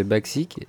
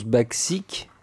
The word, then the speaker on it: Bacsik: Olga, comment on prononce Parce que nous, on le prononce Baxik, C'est B-A-C-S-I-K, mais on dit vraiment Baxik je suppose quoi, moi.